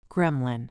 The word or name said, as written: gremlin